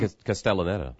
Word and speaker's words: Castellaneta: Dan Castellaneta.